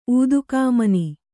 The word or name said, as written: ಊದುಗಾಮನಿ